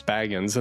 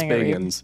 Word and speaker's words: Bagans: I was like, it's Bagans. And I was like, oh, wait, no, it's Bagans. I forgot. It's Bagans. – Okay. – Remember we learned that? – I thought I was saying it wrong. Yeah, I thought I was saying it wrong. – No, it is Bagans.